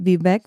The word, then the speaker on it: Vivek: Da hat er im Englischen gesagt, sein Vorname Vivek würde sich auf Cake, also auf Kuchen reimen.